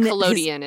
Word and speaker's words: collodion: I read that his face was actually physically immobilized by putting colloidin, I think it's pronounced, in collodion. It's a photographic chemical.